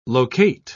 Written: locate

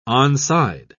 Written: onside

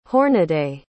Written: hornaday

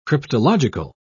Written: cryptological

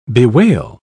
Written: bewail

